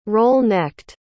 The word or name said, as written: roll-necked